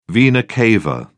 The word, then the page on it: vena cava: British English